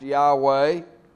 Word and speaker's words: Yahweh: The point of the sermon, he's made Lord, the Greek word kuros, the Hebrew word, the unspeakable name of God, YHWH, Yahweh, that we think is probably pronounced Yahweh, that he has made him both Lord, Kyrios, and Christ, Christos, Lord and Master.